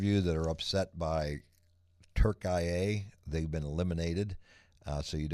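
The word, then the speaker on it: Türkiye: I've had a lot of people texting me, a very limited group though, upset about them referring to Turkey, who's been eliminated, as Turkiye now, which is the correct pronunciation that the Turks call their country, which is fine.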